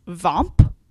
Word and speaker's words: VOEMP: But I want to get one acronym out there before our conversation next time, which is VOMP, which is just kind of fun to say. VOMPing is the gerund form. So VOMP is spelled VOEMP.